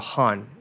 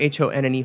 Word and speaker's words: HONNE: And I wrote that with this uh, British duo named HONNE. Do you know H O N N E HONNE? Yeah. Is that how they say it in Thai? In Korea they say honne. So I just don't know.